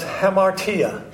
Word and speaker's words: hamartia: It's pronounced hamartia.